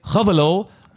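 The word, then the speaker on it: Gouweleeuw: Jeffrey Hovelow, und den darfst du jetzt buchstabieren.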